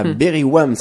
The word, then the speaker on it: Berywam: Voilà, Berry Wham, ça s'écrit BERYWAM.